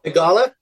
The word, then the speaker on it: Theegala: I don't know if I'm going to pronounce his name correctly, but is it Sahith Theegala. – Theegala.